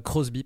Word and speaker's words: Crosby: Shabaka, il est issu d'un programme nommé Tomorrow's Warriors, qui a été fondé en 1991 par Janine Irons et Gary, et Gary Grosby. Crosby, pardon. Crosby, je ne sais pas la prononciation. Je dirais Crosby. ouais, Crosby. Avec, un, avec un Y.